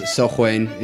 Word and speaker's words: Samhain: it's actually pronounced Sochwain in Gaelic